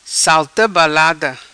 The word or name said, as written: Salta Balada